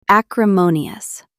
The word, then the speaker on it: acrimonious: Stress the third syllable. Acrimonious. Say it aloud. Acrimonious.